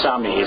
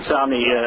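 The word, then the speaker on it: Samnee: Chris Somney. I always pronounce his name right. I've known him for years. Somney, beautiful art on that.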